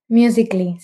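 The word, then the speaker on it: Musical.ly: Oye, tú dijiste que empezaste también en, la verdad no sé cómo se pronuncia, Musically. – Musically, sí.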